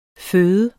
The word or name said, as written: føde